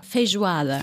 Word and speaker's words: Feijoada: Du hast auch mal gesagt, äh, eines deiner Lieblingsessen ist, und ich werde das jetzt falsch aussprechen, aber Feijoada. – Feijoada.